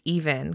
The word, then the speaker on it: EVNNE: The group, I think that their name is pronounced Even, because we talked about them before. It's like E-V-N-N-E.